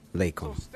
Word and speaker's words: alaikum: Rabia Muhammad says there is also an accented ooh sound on the last syllable of alaikum in the president's pronunciation.